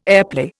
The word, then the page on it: airplay: Indian